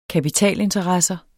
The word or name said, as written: kapitalinteresser